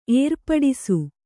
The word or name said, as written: ಏರ್ಪಡಿಸು